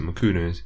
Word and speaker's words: Mchunu: In case anyone wonders um, about the pronunciation there, um, I've seen the name pronounced Makunu and Makunu, but um, if you if you find a fight on YouTube, there's some of his older fights as well. The South African commentators seem to pronounce it Makunu. Um, that's what the commentators on the stream this weekend were using as well, and um, so that's what I'm going to roll with.